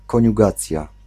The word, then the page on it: koniugacja: [kɔɲuˈɡat͡sja]